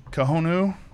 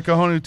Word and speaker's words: Cojonu: So it's a tatuaje cojonu, I think is how you would pronounce it. C-O-J-O-N-U. It's like cajon, like the drum, but with a U on the end.